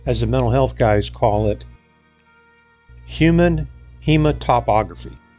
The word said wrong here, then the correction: hematophagy: That's pronounced human hematophagy.